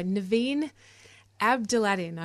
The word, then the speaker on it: Niveen Abdelatty: We are joined in the studio by Niveen Abdelatty.